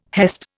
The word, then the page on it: hest: Indian